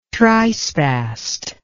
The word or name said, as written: trispast